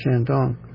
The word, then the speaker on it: Shandong: There's Shandong. It's two even-pitched syllables.